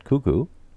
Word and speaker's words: Koekkoek: K-O-E-K-K-O-E-K. – Wait, say that again? – K-O-E-K-K-O-E-K. – I don't know. – It's pronounced Cuckoo.